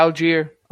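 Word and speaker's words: Allgeier: I like Tyler how do you say his name? Tyler Algier. I like him. – Yeah, Algier.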